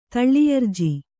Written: ತಳ್ಳಿಯರ್ಜಿ

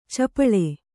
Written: ಛಪ್ಪಳೆ

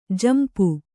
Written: ಝಂಪ

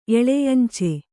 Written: ಎಳೆಯಂಚೆ

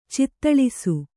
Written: ಚಿತ್ತಳಿಸು

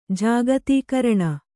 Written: ಜಾಗತೀಕರಣ

